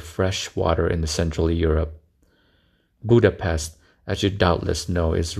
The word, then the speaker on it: Budapest: If you want to be fancy like a radio announcer, the proper pronunciation is Budapest.